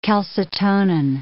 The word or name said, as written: calcitonin